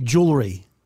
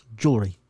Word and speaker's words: jewelry: No, jewelry. Two syllables, jewelry. It's not jewelry.